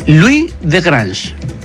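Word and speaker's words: Louis de Grange: Es Luis de Grange. – O sea, se escribe igual, Louis. – Louis. – Como la mamá de Malcolm.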